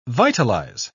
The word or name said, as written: vitalize